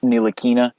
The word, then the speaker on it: Ntilikina: Ntilikina.